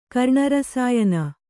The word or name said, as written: ಕರ್ಣರಸಾಯನ